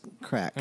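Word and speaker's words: craic: Is it the crack or crake? – C-R-A-I-C. I'm not a... – Yeah, I think in Gaelic that's pronounced crack.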